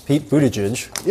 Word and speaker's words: Buttigieg: Pete Very Buttigieg. Close. That was close. – That was Pete Buttigieg's name. – Pete Buttigieg. Yeah. Pete Buttigieg. – Got it. – Buttigieg. Buttigieg. Buttigieg.